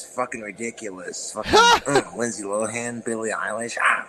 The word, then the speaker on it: Billie Eilish: Dude, he said it right. Dude, normally he says Billie Eilish. But he said it correctly in this private conversation. It wasn't even private, but it wasn't on his channel. I'm like, hold on. You said that right this time.